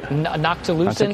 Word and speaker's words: noctilucent: Nocticulant cloud, right? – Noctilucent. – Nocticulant cloud? Noctilucent. Yeah, yeah. I've mispronounced it like a thousand times.